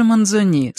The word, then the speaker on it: Манзони: А поэт Манзони, лучше даже Манзони, с ударением на последнем слоге,